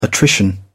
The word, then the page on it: attrition: British English